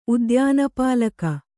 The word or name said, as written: ಉದ್ಯಾನಪಾಲಕ